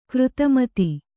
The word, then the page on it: ಗಾರುತ್ಮತ: gārutmata